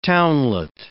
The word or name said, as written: townlet